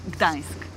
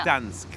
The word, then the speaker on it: Gdańsk: Gdansk, ich kann das gar nicht aussprechen, ist polnisch. – Gdańsk, ja. – Gdańsk. Okay, aber das ist der original polnische Name. – Ja. – Gdańsk. Man spricht nur das D, oder? – Nee. N mit Strich.